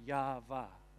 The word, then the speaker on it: Yahweh: They put the vowels in so they could pronounce a word that wasn't as holy, but they say that the pronunciation of Yahweh is literally Yah-Vah,